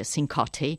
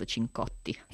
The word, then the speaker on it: Cincotti: E poi vabbè, poi ci racconta che ha una family history, gli americani sentono molto questa cosa, anche se poi appunto il suo cognome lo pronuncia giustamente Cincotti e non certo Cincotti.